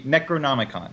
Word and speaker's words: Necronomicon: So, now how do you pronounce that? Just like Necronomicon? – The Necronomicon. – Necronomicon, okay. I've got really bad pronunciation, too.